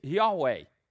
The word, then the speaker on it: Yahweh: And the best guess that scholars have made as to how that's pronounced is Yahweh.